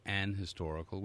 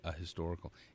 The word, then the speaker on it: historical: But in American English, the H is pronounced, so it should be a historical.